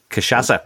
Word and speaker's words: Cachaça: Okay, I presume the Brazilian version is the, I can't pronounce it, like cachaca? Cachaca? – Cachaça.